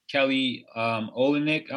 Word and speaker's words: Kelly Olynyk: was by Kelly Olynyk.